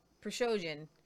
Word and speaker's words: Prigozhin: And there's been this back and forth with Prigozhin. I said it right. Prigozhin, the Wagner group leader, he's got 25,000 troops.